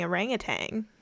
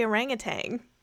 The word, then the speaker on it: orangutan: Hey guys, let us know if you say orangutan or orangutan. – Tan.